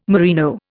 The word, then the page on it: merino: Indian